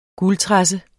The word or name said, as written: guldtresse